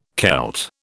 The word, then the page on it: Kelt: British English